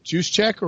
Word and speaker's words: Juszczyk: I can't pronounce his name. – Juszczyk. – Did I say Juszczyk? – It's on Twitter.